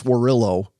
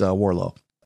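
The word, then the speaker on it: Warrillow: So, and it looks like John Warillo, almost like, you know, Norfolk. You don't, you don't pronounce it Norfolk. You don't pronounce Warillo. It's Warlow.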